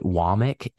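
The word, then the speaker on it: Wommack: Kane Womack, it looks like Womack, but he says it Womack. And there, I actually saw in the pronunciation guide, it says Womack, W-A-H-Mick.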